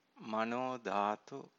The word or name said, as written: manodhatu